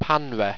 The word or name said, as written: panra